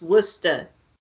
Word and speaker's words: Worcester: You said it wrong. It's Worcester.